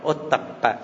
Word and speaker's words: ottappa: Chữ này đọc là ottappa, ottappa là ghê sợ với tội lỗi.